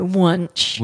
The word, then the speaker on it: Wunsch: By Wunsch. – Which rhymes with lunch.